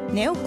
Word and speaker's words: 音: 現在 Google Play ストア、 Android 端末用のアプリケーションとして配信されております電子コミック、ネオコミック、音サウンドの方ですね、音と書いてネオコミックと読みます。